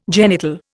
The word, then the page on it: genital: Indian